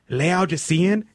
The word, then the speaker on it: Laodicean: She was like, hey, Laodicean is the correct pronunciation.